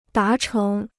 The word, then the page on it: 达成: dá chéng